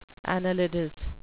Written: annelidous

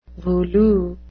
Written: voulu

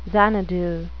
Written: xanadu

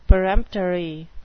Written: peremptory

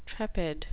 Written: trepid

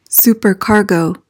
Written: supercargo

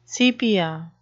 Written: sepia

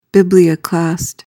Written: biblioclast

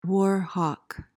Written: war hawk

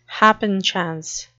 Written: happenchance

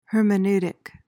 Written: hermeneutic

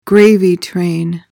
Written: gravy train

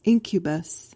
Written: incubus

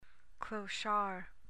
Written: clochard

